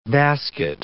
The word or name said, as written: basket